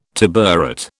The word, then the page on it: tabouret: British English